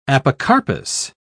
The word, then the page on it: apocarpous: British English